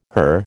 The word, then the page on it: her: American English